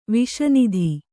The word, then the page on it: ವಿಷನಿಧಿ: Kannada